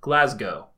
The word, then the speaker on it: Glasgow: And before it comes up, Glasgow is pronounced Glasgow.